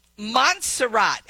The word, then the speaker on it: Montserrat: Okay, Joni, Montserrat. Am I saying that right? – That's how I say it.